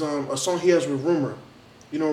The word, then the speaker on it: RMR: The RMR, but they say his name is Rumor.